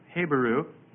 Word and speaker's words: Habiru: That's spelled H-A-B-I-R-U, Habaru. And many scholars feel that this is the same word as Hebrew, Habaru. Habaru sounds very similar to Hebrew.